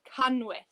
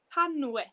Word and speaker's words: canwyll: So we have a double L in the candle in Welsh, and it's pronounced sh. Yeah, and a lot of people can struggle to say that. That's why Welsh is a bit harder, but we say canwyll.